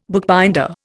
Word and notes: bookbinder: Indian

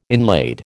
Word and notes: inlaid: American English

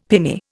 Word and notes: pinny: Indian

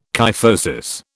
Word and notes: kyphosis: British English

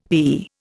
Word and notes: thee: Indian

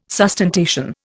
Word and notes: sustentation: Indian

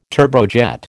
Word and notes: turbojet: American English

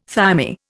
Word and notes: thymi: Indian

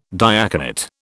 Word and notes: diaconate: British English

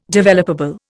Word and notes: developable: Indian

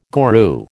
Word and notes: guru: American English

